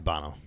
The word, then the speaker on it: Bono: I don't know if it's Frankie Bono or Bono. B-O-N-O. How do you say it? – It's got to be Bono.